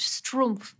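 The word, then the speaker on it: Schtroumpf: Yes, and in French, it's pronounced strumpf.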